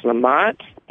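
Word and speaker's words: Lamat: So it is pronounced Lamont.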